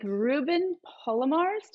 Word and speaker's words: Ruben Palomares: I am here with Ruben Palomares. Did I say your name right? – Yes, he did.